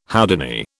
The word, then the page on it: Houdini: British English